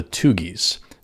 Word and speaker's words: Thuggee: So that leads us to the third group I wanted to touch on today, and that's the group called the Tugis. Now, you've probably heard this word before, but may not recognize it with this pronunciation. It's actually spelled T-H-U-G-G-E-E, but it's actually where we get our word thug from, T-H-U-G.